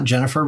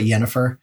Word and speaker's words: Yennefer: There's also a female character named Yennefer, not Jennifer, but Yennefer.